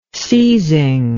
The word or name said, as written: seizing